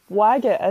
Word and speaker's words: Waggett: I was just going to say his name's Waggett, isn't he? Like, like wagging your finger in someone's face when you're telling them off.